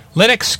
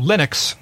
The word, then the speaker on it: Linux: Linux came out in August 25th of 1991, again about 4 years before Windows 95.